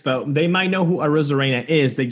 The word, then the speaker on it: Arozarena: I think I just got Arozarena. – Arozarena. – Arozarena.